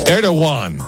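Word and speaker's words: Erdoğan: Erdogan. That's how you pronounce it.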